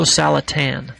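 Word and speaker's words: Salatin: There's a gentleman we interviewed a number of months ago, and it'll be archived on our website, Joel Salastine. I'm sorry, I'm getting his last name wrong, but Polyface Farms, Joe Salatan, that's it, Polyface Farms in Virginia.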